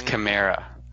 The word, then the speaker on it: Kamara: When are you going to pronounce his name correctly? It's Kamara.